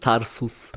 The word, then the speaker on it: Tarsus: Habe ich es richtig ausgesprochen? – Genau, hast es fast richtig ausgesprochen. Tarsus. – Tarsus.